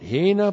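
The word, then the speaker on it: Hina (ἵνα): All right, hina plus the subjunctive. H-I-N-A.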